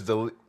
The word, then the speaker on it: D'Elia: It's Chris D'Elia.